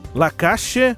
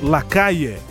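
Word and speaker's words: Lacalle: Lacache para a região do Prata, Buenos Aires. No geral, a gente ouve também bastante Lacaille. Você escolhe a pronúncia Muito do do espanhol.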